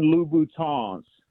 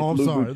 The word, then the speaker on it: Louboutin: And then most importantly, so the women that listen to the show don't start tagging you on YouTube, they are Christian Louboutins. You got to pronounce it with the French Louboutin.